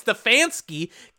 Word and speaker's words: Stefanski: Oh my God, and they fucking get the name wrong as they announce it. It's Stefanski, Kevin Stefanski of the Cleveland Browns, over Campbell and D'Amico Ryans, over Kyle Shanahan.